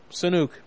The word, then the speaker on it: Sanuk: You know, Sanuk. Check it out. – How'd you spell it? – S-A-N-U-K.